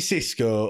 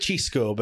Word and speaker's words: Xisco: Actually, on that, is it Cisco or is it Chisco? Well, he, he's told us how to pronounce it on it. He said Chisco, but I mean, we're not, we're not bothered about that.